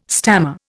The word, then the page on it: stammer: Indian